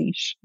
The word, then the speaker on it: niche: So they tend to say niche. It's not like a little cutout of a tree. It's a niche. Origins of are French. So yeah, it's pronounced niche.